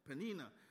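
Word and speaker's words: Peninnah: I said it wrong the first time. Penina, she was cruel and brought Hannah low.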